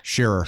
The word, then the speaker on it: Sherrer: Sherrer. – Sherrer. – Sherrer. – Sherrer. – What's like we're sharing something.